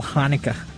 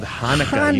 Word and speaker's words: Hanukkah: It's called Hanukkah, you say. Got to say it like that? – Yeah. – Okay, noted. Yeah. Writing that down, you got to get that glottal stuff in there.